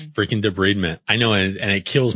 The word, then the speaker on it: debridement: It's not debridement, it's debridement. It's always debridement. 100 bajillion % of the time, except for in dentistry. We kind of make up our own thing. – How to pronounce debridement, YouTube. – Yeah, see if you can play it. See? – Damn, it is debridement.